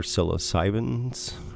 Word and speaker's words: psilocybin: I think they're psilocybins or whatever. – Psilocybin. Let's pronounce it correctly.